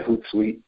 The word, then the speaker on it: Hootsuite: was it Hootsuite? Is that how you pronounce it? – Yeah, Hootsuite, yes.